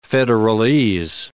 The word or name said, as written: federalese